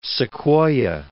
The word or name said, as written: Sequoyah